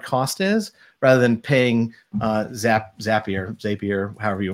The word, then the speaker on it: Zapier: It's pronounced as it's a zap. You're creating zaps. So it's Zapier.